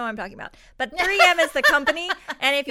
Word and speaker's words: 3M: But all it is 3 millimeter. Did I say that right? – No, it's 3M is the company.